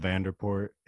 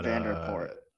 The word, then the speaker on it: Vanderpoort: Yeah, it's just how it's spelled, Vanderpoort. It's not Vanderpoort.